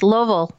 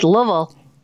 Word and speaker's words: Louisville: No, it's Louisville. It's one syllable. – It's not. – It's Louisville.